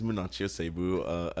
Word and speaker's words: Munachi Osegbu: Well, my name is Munachi Osegbu.